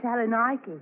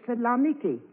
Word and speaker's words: Saloniki: Mine's at Salonika. – Excuse me, Mrs. Haggerty, but the correct pronunciation is Saloniki.